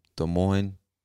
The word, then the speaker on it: Des Moines: And it's spelled, just in case you're not watching, D-E-S space M-O-I-N-E-S, Des Moines. Des Moines, Iowa. Actually, I think the S is silent. Des Moines, Iowa.